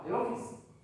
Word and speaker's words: The Office: Estamos, estamos, hay, una serie que me encanta, que se llama The Office, la, The Office.